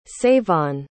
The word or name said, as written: savon